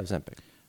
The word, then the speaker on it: Ozempic: Ozempic. Is that how you pronounce it? – Yeah, Ozempic.